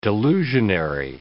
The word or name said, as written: delusionary